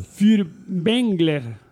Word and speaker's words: Furtwängler: Wilhelm Fürbengel. – ¿Qué pasa? Si lo pronuncio bien. ¿Furtwängler o Furtwängel? – Furtwängler.